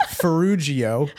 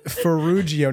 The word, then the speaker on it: Ferrugio: It's just to clarify, it's Ferrugio. Ferrugio, not Ferrugio. – I don't know if it was a hard or soft G. – I mean, you know, it depends on the situation, but yes, normally it's Ferrugio.